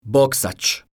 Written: boksac

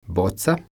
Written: boca

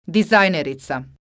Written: dizajnerica

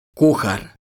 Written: kuhar

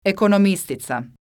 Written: ekonomistica

